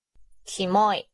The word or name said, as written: kimoi